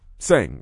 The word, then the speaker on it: Tseng: But I think it's Greg Seng. – Yeah, Seng, right. – Seng. – Right, but it's spelled T-S-E-N-G. He's correct.